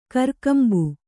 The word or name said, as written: ಕೊರೆಕಂಬಿ